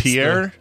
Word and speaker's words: Pierre: It's Pierre, not Pure.